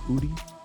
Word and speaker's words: Audi: Wait, it's not pronounced Audi? – It's Audi, bro. Why would it be Audi? – Sorry.